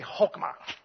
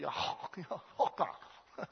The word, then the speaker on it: chokmah: Say chokmah. Say it like you got a lunger in your throat. Chokmah.